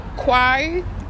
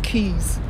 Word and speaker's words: Quay: The way it's spelled, like Q-U-A-Y. I used to say Quai. Like, people be like, what the fuck is that, Quai? But I say things how it's spelled, all right? So, Quai, but, or Quay. Yeah, Quai or Quay. West Indian Quay, but I know it's Keys. It's like, Keys is K-E-Y-S, Keys.